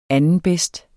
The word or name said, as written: andenbedst